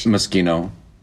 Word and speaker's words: Moschino: M-O-S-C-H. – I would say Moschino.